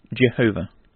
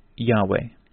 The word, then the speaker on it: Yahweh: From this, they took the signs or points and attached them to Yahweh, thereby giving it to the incorrect orthography Jehovah. All critics, however, are now agreed that the true pronunciation of the word is Yahweh,